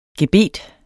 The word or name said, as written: Gebeterne